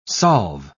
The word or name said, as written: solve